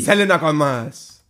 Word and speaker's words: Selena Gomez: Selena Gomez.